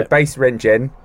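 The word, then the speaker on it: Basse-Rentgen: B-A-S-S-E hyphen R-E-N-T-G-E-N. – B-A-S-S-E. – Space R-E-N-T-G-E-N. – Oh, yeah, Bas-Rentgen. – There you go, Bas-Rentgen.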